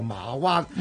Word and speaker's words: 馬灣: 咁但係通常佢哋發音咧就唔會讀阿媽字嘅，讀個馬字。 – 係，所以咧慢慢嗰個地方就叫做馬灣。